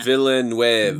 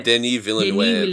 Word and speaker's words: Villeneuve: Villeneuve. – Villeneuve. – Denis Villeneuve. Denis Villeneuve. – Denis Villeneuve. – Denis Villeneuve.